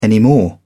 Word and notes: anymore: British English

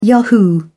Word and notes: yahoo: British English

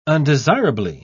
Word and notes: undesirably: British English

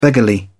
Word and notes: beggarly: British English

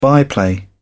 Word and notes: byplay: British English

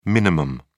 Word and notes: min: British English

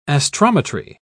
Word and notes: astrometry: British English

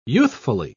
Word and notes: youthfully: British English

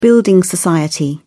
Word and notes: building society: British English

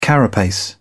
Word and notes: carapace: British English

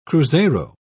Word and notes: cruzeiro: British English

